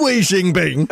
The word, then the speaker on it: Xi Jinping: Which is pronounced... When Xi Jinping... – to pronounce it correctly, Xi Jinping. So,